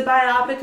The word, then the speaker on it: biopic: It's a biopic about a singer I don't care about. – Yeah. – Which apparently that's pronounced biopic, not biopic.